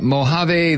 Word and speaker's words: Mojave: James is calling from Mojave Valley, Arizona.